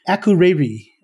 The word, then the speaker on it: Akureyri: Akureyri, I think, is close to what it's supposed to be.